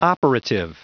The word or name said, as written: operative